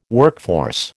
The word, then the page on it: workforce: American English